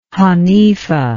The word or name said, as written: Hanifah